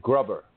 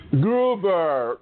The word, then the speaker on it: Gruber: Okay, Gruber. – Gruber. – Gruber. Diane Gruber. L. Gruber. It's German.